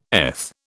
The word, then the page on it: eth: British English